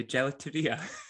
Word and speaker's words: gelateria: So I forgot how you say it again, the gelateria.